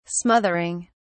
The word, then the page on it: smothering: British English